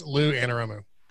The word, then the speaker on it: Lou Anarumo: For your correction, it's Lou Anarumo. – Who? – Anarumo. Lou Anarumo. Rumo? I'm probably saying that wrong. L-O-U, yeah.